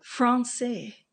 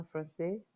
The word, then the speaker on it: français: What do you mean in Francois? Who's Francois? – The Festival du Voyage. I think most of it's in French. I'm pronouncing that. I butchered that, but Francais. En français. – Francois. – Francois is a name. – Francois is actually French, no? En français. En Francois. Francais.